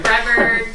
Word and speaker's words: Brevard: Usually people say Bernard, Boulevard, and like... – Somewhere. – Brevard.